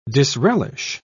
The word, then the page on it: disrelish: British English